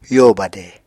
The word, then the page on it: yo bade: apatani